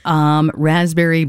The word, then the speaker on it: raspberry: So, Raspberry Beret by Prince, probably not your favorite. Does it remind you? Or if you eat a raspberry, you're like, it's just like, ugh, silent P.